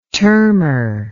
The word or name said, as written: termor